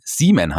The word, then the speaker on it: Seaman: Der spätere Merman sollte übrigens erst Seaman heißen. Und das ist ja quasi, wird ja genauso ausgesprochen wie Samen.